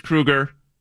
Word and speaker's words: Kruger: Nice work today, Chris Kruger.